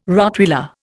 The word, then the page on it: Rottweiler: Indian